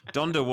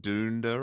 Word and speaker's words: Donda: Donda was the correct pronunciation, though. Aiden? – I believe so. Yeah, yeah. – It's not Dunda or no, so, something.